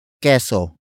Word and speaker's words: keso: It is an object marker that introduces what Jessica cut with a knife for the beneficiary, and that is queso. Queso. Queso. Queso is spelled as K-E-S-O.